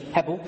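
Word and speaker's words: Hebel (הבל): The Romanians say Abel, which actually is probably closer to the Hebrew pronunciation of Hebel.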